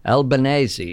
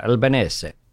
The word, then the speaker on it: Albanese: Albanese is the correct pronunciation, right. – Of course. You always say the vowels at the end of Italian words. – That's right. – But I've said before, I'm not terribly precious about how people pronounce my name. No one says Bolognese when they order spaghetti Bolognese, but they should, of course. And it's a bit more of a silent S, really, when you do the proper Italian.